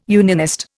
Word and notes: unionist: Indian

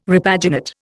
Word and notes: repaginate: Indian

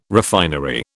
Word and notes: refinery: British English

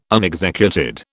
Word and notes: unexecuted: British English

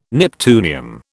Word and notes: neptunium: British English

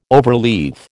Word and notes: overleaf: American English

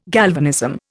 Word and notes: galvanism: Indian

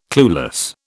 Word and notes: clueless: British English

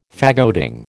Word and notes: faggoting: American English

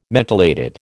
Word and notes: mentholated: American English

